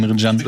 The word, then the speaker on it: Emre Can: Emre Can wird Can ausgesprochen.